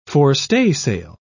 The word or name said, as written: forestaysail